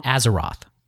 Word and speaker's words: Azeroth: Azeroth. – What now? – Azeroth.